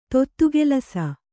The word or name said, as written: ತೊತ್ತುಗೆಲಸ